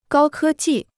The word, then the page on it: 高科技: gāo kē jì